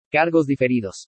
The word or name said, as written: CARGOS DIFERIDOS